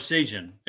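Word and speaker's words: Parseghian: Is that how you pronounce his last name? I never got it right. – Parsegian.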